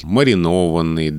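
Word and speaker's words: маринованный: Потому что все слова на «ованный» — «маринован», да, там, не знаю, костюмированный, вот именно с таким ударением. Сейчас можно опять открыть.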